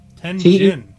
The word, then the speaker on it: Tenjin: Goryeo is the the Shinto kami known as Tenshin. Government official Shugawara and Mishizane. What? – You say Tenshin? – No. Tenjin. – Tenjin. – Yeah, it's a J, not a Shin. – Tenjin.